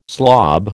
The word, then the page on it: slob: American English